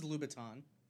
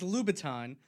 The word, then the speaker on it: Louboutin: Oh, this is Louboutin. – Yes, those are the shoes I was talking about. – I mean, we don't need to know, because it's Louboutin.